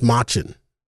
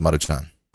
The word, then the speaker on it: Maruchan: I've always thought that was pronounced Machin. – No, it's Maruchan.